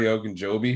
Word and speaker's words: Ogunjobi: They did lose Larry Ogunjobi, who was huge for them.